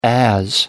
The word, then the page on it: as: American English